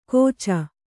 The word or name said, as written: ಕೋಚ್